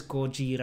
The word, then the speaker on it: Gojira: Yeah, in katakana it says Gojira. – Yeah, Godzilla. – Which is the Japanese pronunciation of Godzilla.